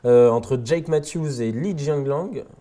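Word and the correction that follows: Li Jingliang: Jing Liang, Pardon, on va prononcer Tu vas la refaire depuis le début Sur Li Jingyang.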